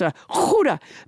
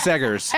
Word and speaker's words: Seghers: I don't know how Peter pronounces his last name. I believe it's Huda. Peter. – It's Segers. – S-E-G-H-E-R-S, which I would pronounce that Huda.